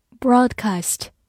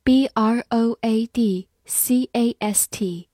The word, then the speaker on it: broadcast: aged, elderly, broadcast, b r o a d c a s t, broadcast b r o a d 发 broad，c a s t cast，broadcast，broadcast，美式发音当中字母 a 发大口的 a，broadcast 也是可以的。